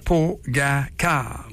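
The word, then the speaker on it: Pogačar: Pogacar. On prononce ça comme ça, ouais? Ouais.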